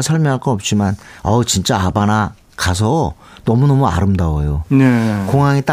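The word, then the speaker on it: Habana: 어, 스페링은 H가 있습니다만 발음은 아바나가 맞죠.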